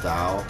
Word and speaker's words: Tao: Have you ever read the Tao, the Book of Tao? – Isn't it like a... – Like T-A-O. It's spelled, but it's pronounced Tao.